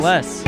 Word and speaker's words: Blas: Bless. – Bless? – Bless. – How do you spell that? – B-L-A-S. – Oh. – B-L-A-S.